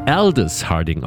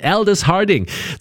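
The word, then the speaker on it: Aldous Harding: Sie wird Aldis Harding ausgesprochen, nicht Aldis Harding. Ich weiß gar nicht, warum ich immer daraus so ein langes A machen möchte. Aldis Harding.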